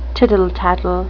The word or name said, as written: tittle-tattle